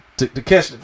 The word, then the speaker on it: Takeshita: Takeshita, thank you.